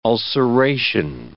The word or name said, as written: ulceration